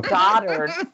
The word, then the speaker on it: dotard: Dotard. Dotard is how it's pronounced.